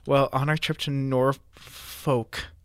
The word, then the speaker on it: Norfolk: L is not to be pronounced. – So is the L in Norfolk not supposed to be pronounced? – Norfolk.